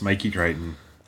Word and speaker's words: Crichton: Ongoing, never ending exploration of and with and by and of the works of Michael Crichton. Did I say that right? – Yes, Mikey Crichton.